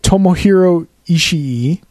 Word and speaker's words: Tomohiro Ishii: A headbutt from Tomohiro Ishii.